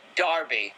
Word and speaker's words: derby: The weird part is, it's spelled out as the word derby, like Home Run Derby, Kentucky Derby, but they pronounce it Darby.